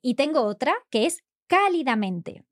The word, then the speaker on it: cálidamente: So it has an accent on the first A, cálidamente.